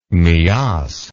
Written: Miass

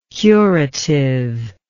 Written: curativeness

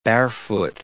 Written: barefoot

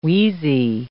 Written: wheezy

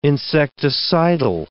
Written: insecticidal

